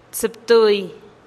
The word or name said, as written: tsïptui